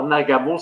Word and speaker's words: Nagamo: Alors, Nagamo, ça s'écrit N-A-G-A-M-O, comme ça se prononce.